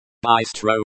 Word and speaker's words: быстро: Быстро, this is an adverb meaning quickly or rapidly. The stress is on the first syllable, by. Think of it as быстро.